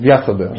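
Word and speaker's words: Вьясадева: Кстати говоря, по поводу вот, книга, например, Бхагавадгита, она считается э, литературным воплощением Литературным воплощением Васудева является. Да, Вясадева.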